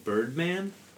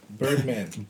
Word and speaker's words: Bergman: Amanda Bergman. – And the album was? – Docs. – Nice. – I said that to someone, and they said, are you saying Birdman? – Bergman. – Bergman. – Harvey Bergman. – Yeah. – B-E-R-G-M-A-N.